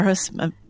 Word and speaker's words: Aarhus: Am I pronouncing Aarhus, that? – Yeah. – Aarhus.